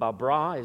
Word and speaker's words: Babra: Some of these names, they're trying, but Babra is the right pronunciation of that.